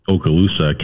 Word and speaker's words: Okaloosa: Okaloosa, is that how you say it? – Okaloosa County Sheriff's said, sorry, my eyes are still trying to adjust.